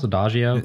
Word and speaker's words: Adagio: Adagio? – That's correct, yep.